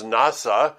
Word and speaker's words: naso: This word is one that we will go to numerous times through the Scripture, is the word naso, and it's interesting to me that it's pronounced many times NASA, which is very similar to the National Aeronautic Space Authority.